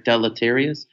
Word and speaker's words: deleterious: It starts to have a negative effect or a deleterious. I always want to use that word. – Deleterious? Yeah, I think you pronounced it right.